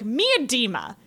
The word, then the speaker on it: Miedema: And before the last podcast, Allison and I looked up on several videos how to say her name, and there was like a how to pronouncer, and it was like Miedema.